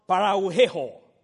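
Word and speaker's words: pararrhueo: The word drift in the Greek is spelled P-A-R-A-R-R-H-U-E-O. P-A-R-A-R-R-H-U-E-O. It's pronounced parauheho. Parauheho. That's the way it's pronounced.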